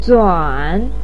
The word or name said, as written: zuan3